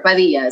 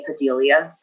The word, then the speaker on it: Padilla: Hello, is this Maria Padilla? Padilla, or Padilla is how you say her name in Spanish. And she hates it when people call and say Padilla.